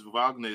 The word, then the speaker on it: Wagner: And also, Franz Wagner, I think that's how you pronounce Wagner.